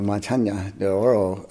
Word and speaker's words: Montaña de Oro: Montaña de Oro. I've got to pronounce it more correctly. After I've noticed you were all using the Inya properly. Yes. So Montaña de Oro is so extensive, and it has so much variety, and it has mountains, of course.